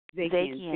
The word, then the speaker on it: Zakian: And, with Megan Sandberg, is it Zakian? Is that how you pronounce her last name? – Zakian. Sandberg Zakian.